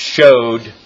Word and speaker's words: shew: I think that it should always be pronounced showed or show.